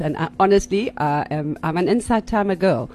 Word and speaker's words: Insight Timer: Insight Timer. Not Inside Timer.